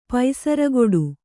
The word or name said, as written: ಪೈಸರಗೊಡು